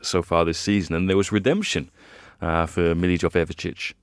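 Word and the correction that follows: Milivojević: I thought it was Milivojevic.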